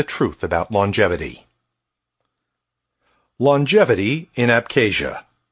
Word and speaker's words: Abkhazia: And in all the Caucasus, the area most renowned for its extraordinary number of healthy centenarians, people above the age of 100, was Abkhazia, pronounced Abkhazia.